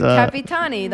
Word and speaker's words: Capitani: On the 11th, it's the Capitano, and it's Capitani. That was close.